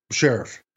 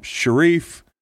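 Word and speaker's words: Scherff: I think it's Sheriff. – I've had D-line coaches call him Sharif. – It's not Sharif. – Sheriff. Sheriff. Sheriff.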